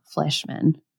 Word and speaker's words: Fleischmann: And other people, I watched a lot of like YouTube videos on this of people covering it and like interviews, but people pronounce the name differently. So I'm going to say Fleshman. It's kind of spelled Fleshman. I don't know. F-L-E-I-S-C-H. M-A-N-N, Fleshman.